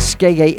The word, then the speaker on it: Skeggae: Google it, Skeggy Reggae, and Skeggy is spelt the same as reggae. S-K-E-G-G-A-E. Skeggy Reggae.